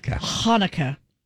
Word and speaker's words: Chanukah: The Hebrew word is actually pronounced with a guttural Hanukkah.